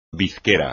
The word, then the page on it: bizquera: espagnol